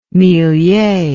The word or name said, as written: millier